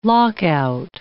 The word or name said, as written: lockout